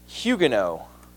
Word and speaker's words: Huguenot: In France, the Protestants there are called Huguenots, spelled Huguenots, but pronounced Huguenot.